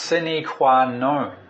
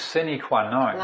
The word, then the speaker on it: sine qua non: It's pronounced sine qua non. It looks like non, but we pronounce non, so it's sine qua non.